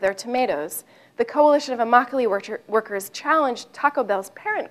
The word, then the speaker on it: Immokalee: This is a modern-day David and Goliath story in which a small organization of farm workers in South Florida called the Immokalee Workers, rhymes with broccoli, gained international significance by forcing the fast food industry to respect the labor rights of tomato pickers.